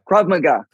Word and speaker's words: Krav Maga: Man, it's And so we're going back to the Krav Maga, Mega or Maga? How do you say it? Krav Maga? – Krav Maga. – Krav Maga. Krav Maga. Krav Maga. Yep.